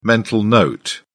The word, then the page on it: mental note: British English